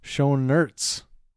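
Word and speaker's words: Schoenaerts: Did you say his name was Schoenertz? – Schoenertz. – Like, show us your nuts? – No. Schoenertz. Franz Rogowski, Karl Markowitz, and Bruno Ganz, which I believe this is his final film credit.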